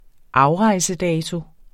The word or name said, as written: afrejsedato